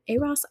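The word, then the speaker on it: Euros: Eros? I think you said it right.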